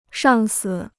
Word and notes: 上司: shàng si

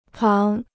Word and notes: 旁: páng

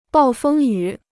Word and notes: 暴风雨: bào fēng yǔ

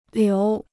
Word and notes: 流: liú

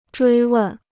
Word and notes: 追问: zhuī wèn

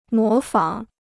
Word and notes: 模仿: mó fǎng

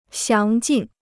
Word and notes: 详尽: xiáng jìn

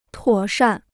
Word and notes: 妥善: tuǒ shàn

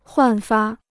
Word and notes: 焕发: huàn fā

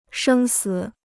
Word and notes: 生死: shēng sǐ

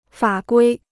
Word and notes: 法规: fǎ guī

